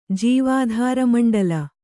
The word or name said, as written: ಜೀವಾಧಾರಮಂಡಲ